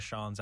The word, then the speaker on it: Sean: But anytime someone reads my name, at least tries to read it phonetically, I always get seen. And why is that? It's because my name is spelled the very Irish way, using the traditional Gaelic spelling of S-E-A-N.